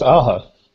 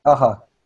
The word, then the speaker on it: Aja: I think it's Aja. – Aja. Yeah, like so. The 80s. Band.